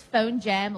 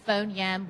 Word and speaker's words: Phonium: Phonium, and not many people know how to pronounce it properly, but yes. Phonium is how you pronounce it. But whether it's Phone Jam or Phone Yam, we're very, very happy. Call it whatever you like.